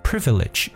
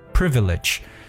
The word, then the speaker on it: privilege: 那么当然，去掉 under privileged 就表示有权势的。嗯，有特权的一层意思。因为 privilege 这个词本身呢，就表示特权。所以大家记住这个词， privilege 这个词读的时候还要特别注意一下它的读音。 privilege，中音在前。 privilege，那么 underprivileged 就是没有特权的，大家可以理解为弱势的。